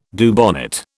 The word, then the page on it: Dubonnet: British English